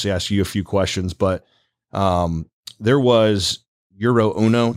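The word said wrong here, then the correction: Jiro: Did I say that? – Giro. – Okay.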